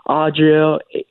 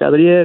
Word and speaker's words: Adriel: Honestly, just, when I was, going to school, you would have, substitutes, even regular teachers would be mispronouncing my name, saying Adriel, just not being able to pronounce it, But when it comes to, family, they're able to say it because, my family speaks Spanish, so they're like, Adriel, Adriel.